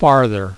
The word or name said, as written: farther